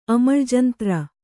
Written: ಅಮಳ್ಜಂತ್ರ

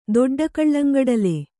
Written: ದೊಡ್ಡಕಳ್ಳಂಗಡಲೆ

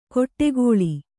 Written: ಕೊಟ್ಟೆಗೋಳಿ

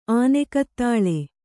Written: ಆನೆಕತ್ತಾಳೆ

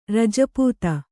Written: ರಜಪುತ

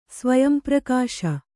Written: ಸ್ವಯಂಪ್ರಕಾಶ

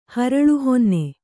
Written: ಹರಳುಹೊನ್ನೆ

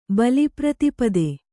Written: ಬಲಿಪ್ರತಿಪದೆ

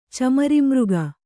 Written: ಚಮರಿಮೃಗ

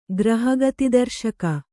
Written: ಗ್ರಹಗತಿದರ್ಶಕ

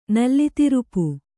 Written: ನಲ್ಲಿತಿರುಪು

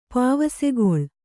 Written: ಪಾವಸೆಗೊಳ್

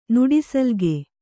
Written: ನುಡಿಸಲ್ಗೆ

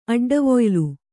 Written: ಅಡ್ಡವೊಯ್ಲು

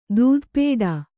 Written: ಧಾತುಪಾಠ

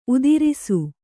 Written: ಉತ್ತರಸ

